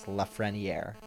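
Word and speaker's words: Lafrenière: I saw somebody tweet out Alexis Lefrenyr, Lafrenyr, and it's perfect because that's exactly how his name is pronounced. It's Lefrenyr, NYR.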